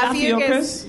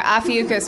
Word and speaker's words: Ophiuchus: Officius, am I pronouncing it wrong? Ophiuchus. What is it? Ophiuchus? – I know, right? – Adam Goldberg to the rescue. – Is it Ophiuchus? – Yep. – Okay, Ophiuchus, which is November 29th to December 17th, and I was born on December 5th, so.